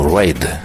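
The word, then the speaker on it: Ryde: Маккартни потом шутил, что это другой город имелся в виду под названием Райд. Пишется только иначе, а звучит ровно так же.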